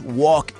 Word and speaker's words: walk: También cuando decimos walk, que es caminar, no se pronuncia la L.